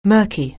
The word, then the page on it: murky: anglais